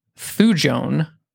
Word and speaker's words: thujone: So supposedly the thing that exists, it's a what is it called, it's a terpene compound that's present in wormwood, which is known as thujone. I think is how you say that.